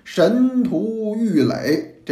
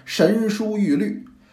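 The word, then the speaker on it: 神荼郁垒: 他要限制鬼的出行，神荼玉垒，这是普通的读音，咱们要读的要读成神书玉律。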